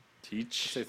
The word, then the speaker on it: Teach: How do you pronounce it? Is it Teach? – Teach.